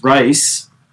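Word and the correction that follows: Rhys: I think it's Reese. – Might be Reese. I think you're right.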